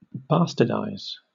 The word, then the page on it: bastardize: British English